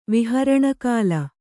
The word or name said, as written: ವಿಹರಣಕಾಲ